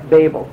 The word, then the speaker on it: Babel: Because, by the way, in the Hebrew, it's Babel. It's actually Babel. It's a long A there, Babel.